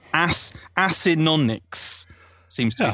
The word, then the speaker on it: Acinonyx: So you don't just say Akinogs, like you're some kind of Akinogs.